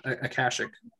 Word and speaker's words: Akashic: Okay, yeah, and that must be a typo because it says Akashic instead of Akastic. – I was probably just saying it wrong, but yeah, Akashic.